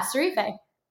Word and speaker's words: Sarife: Did I pronounce this correctly? – Yeah, Sarife. – Sarife, perfect. Yes, Katie Sarife.